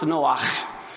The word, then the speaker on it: Noach: Noah really is pronounced Noach.